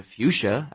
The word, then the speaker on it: fuchsia: No, but here's a fuchsia, Abby said. – What? – That's how it's pronounced, Noah. Fuchsia.